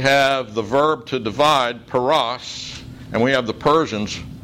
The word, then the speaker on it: paras: The first occurrence is at the beginning of the verse, and it's pronounced peres, half mina. Its second occurrence is a passive verb written in the PL or intensive stem where it's pronounced pirasat and translated divided. And then the third occurrence is the last word in the sentence, paras, which means Persians.